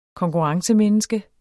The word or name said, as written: konkurrencemenneske